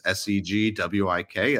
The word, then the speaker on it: SegWik: That's S E G W I K. That's a hard G, not a soft G, SegWik.